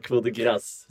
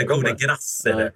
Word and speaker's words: Coup de Grâce: Och då var det någon fransman som, eller någon som gåvade det franska och bara fet rad av Erik Lundin, men det uttalas Coup de Grâce. – Coup de Grâce. – Eller Coup de Grâce.